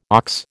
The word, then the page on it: ox: American English